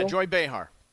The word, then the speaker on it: Joy Behar: Joy Behar. Is that how you say her name from The View? – Yeah, Joy Behar.